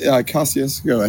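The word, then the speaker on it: Kosciusko: Around Natchez, Kosciuszko, Natchez, in that range? – Yeah, Kosciuszko, I think, is the name, if I'm pronouncing it right. – Yeah, it is.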